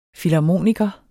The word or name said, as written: filharmoniker